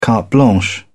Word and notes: carte blanche: British English

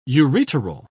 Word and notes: ureteral: British English

